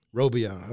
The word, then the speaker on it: Robillard: What's the French pronunciation? – Robillard.